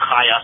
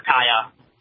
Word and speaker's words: Chaya: Her name is pronounced Chaya, so you got to say the Ches. So people call her Chaya or Kaya. – Oh, no, I don't even know how it's spelled. – Yeah. – Is it C-H? – Yeah, I'll tell you offline how to pronounce it. Yes. – Okay. – It's Hebrew. It's Hebrew with a Chet, but in English we write with a C-H usually, Chaya.